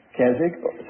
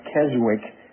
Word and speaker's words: Keswick: They've heard the word Keswick. Sometimes they pronounce it Keswick, but it's Keswick.